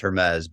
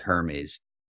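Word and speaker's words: Hermès: It's pronounced Hermes, but when I first saw that word, I thought it was Hermes.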